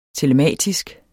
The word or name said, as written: telematisk